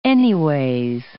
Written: anyways

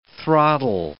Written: throttle

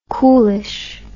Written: coolish

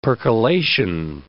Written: percolation

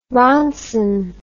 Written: ronson

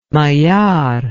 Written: Maillart

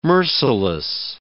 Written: merciless

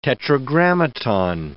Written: tetragrammaton

